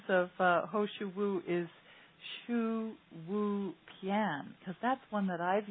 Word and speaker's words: shouwupian: So Quality Life Herbs, they spell it S-H-O-U-W-U-P-I-A-N, Shu-Wu-Pian.